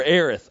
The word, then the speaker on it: Aerith: Or Aerith. I'm sorry. So for the hardcore nerds out there, there is two pronunciations of her name, Aeris and Aerith.